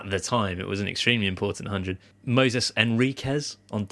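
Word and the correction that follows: Moisés: And it's Moses, but there's an I in there as well. – Moises. – So, yeah. Moises. Sounds like Jackie Gleason.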